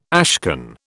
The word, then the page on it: ashcan: British English